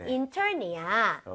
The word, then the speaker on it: intern: I-N-T-E-R-N. 아까 전에는 그냥 넘어갔지만 얘는 발음을 꼭 건드려야 돼요. 중간에 R 있기 때문에 그냥 intern은 안 되겠죠? – 네. – 혀가 뒤로 당겨져서 R 사운드를 낼 기회는 줘야 되는 거 아니에요?